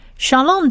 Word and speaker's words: shalom: 再来下一个字是我们最熟悉的 shalom，平安这个字。 shalom 这个字呢有四个字母，第一个字母是希伯来文字母的 shin。训这个字发音发许的音，加上下面小 t 的长母音 r，所以是 sha sha， shalom 的 sha， shalom 的 sha。